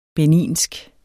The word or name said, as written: beninsk